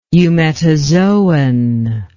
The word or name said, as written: eumetazoan